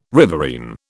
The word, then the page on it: riverine: British English